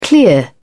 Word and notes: clear off: British English

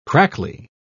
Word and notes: crackly: British English

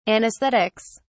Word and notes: anaesthetics: British English

